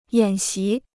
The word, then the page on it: 演习: yǎn xí